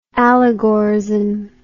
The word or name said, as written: allegorism